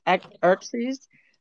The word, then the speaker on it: Xerxes: The X is silent, so it's Xerxes, X-E-R-X-E-S.